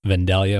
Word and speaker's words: Vandalia: But anyways, Vandalia, Ohio. I think that's how you pronounce it. – Vandalia, Ohio. You got it.